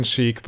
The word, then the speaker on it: Chic: Their name is Iron Sheik, but Sheik is spelled C-H-I-C.